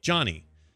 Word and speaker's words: Gianni: There is no J in the Italian alphabet, so to achieve that sound, the letter G-I are used. Therefore, Gianni is not pronounced Gianni. It is simply pronounced as you spelled it in the Americanized way, Johnny.